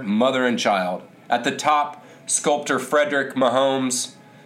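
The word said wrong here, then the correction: MacMonnies: well, a little mispronunciation there, McMonies,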